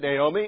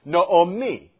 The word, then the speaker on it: Naomi: Naomi, we pronounce it Naomi, is Naomi. Can you say it with me? Naomi. That's how the Hebrew pronunciation is.